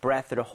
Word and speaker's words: breath: 그래서 본인의 breath를 hold한다는 것은 이거는 숨을 참다가 되겠습니다. – 네네네. – 그리고 이게 breathe라는 동사가 아니에요. 그죠? 숨이라는 명사란 말이에요. 그래서 발음할 때 breathe 이게 아니라 breath.